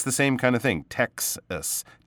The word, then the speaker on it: Texas: Texas. I think that word was uh, mispronounced a little bit. It's like tech, like technology right?